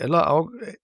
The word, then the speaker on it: Ellerau: Ich folge dir für das, dass die Gemeinde Ellerau war richtig, ja. Oder? Ralf, habe ich es richtig ausgesprochen? – Top, danke. Richtig, genau, hundertprozentig, ja.